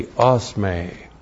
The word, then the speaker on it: osme: O-S-M, long E, osme.